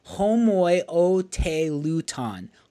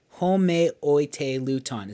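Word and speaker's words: homoioteleuton: And one of those mistakes that we talked about is categorized, and it's called homoiotelutan. Homeoteilouton. It's six syllables.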